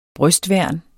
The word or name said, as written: brystværn